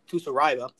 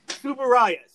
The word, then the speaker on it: Tsuburaya: with special effects by Eiji Tsuraiba, produced and distributed by Toho Studios. Do not fuck with me right now. Yes. Yes. Okay. Tsuburaya.